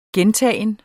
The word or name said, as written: gentagen